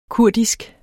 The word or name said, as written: kurdisk